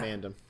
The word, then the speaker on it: Mandom: Yeah, Mandom. – Whatever. – It's like Gundam. That's how they say it.